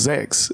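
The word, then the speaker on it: sechs: And they're going, they say, you say, how you say six in German, it's pronounced sex.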